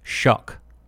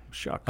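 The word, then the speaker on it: Shough: I looked it up, and it's pronounced Shuck, like Shuck. Rhymes with duck. – Oh. Shuck.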